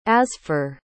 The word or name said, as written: asfour